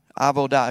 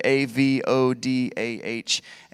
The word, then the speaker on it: avodah: And the Hebrew word for work used here in Genesis 2.15, and I'm probably going to butcher this, but the word is abodah. Avodah. If you were going to spell it like it's phonetically A V O D A H, and I think you pronounce that V like a B, so Avodah, something like that.